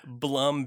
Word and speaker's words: Blum: They make them like, depending on the hinges that you have, like Blum or Bloom. I'm not exactly sure how to pronounce that, but yeah. Blum, B-L-U-M.